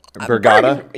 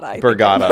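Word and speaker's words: Burga: Am I saying it wrong? – No, you said it right. Something else last time. You're like Bergama or something? – Yeah. Like Bergata? – Bergata. – Bergata. – Bergata. – It's just Berga.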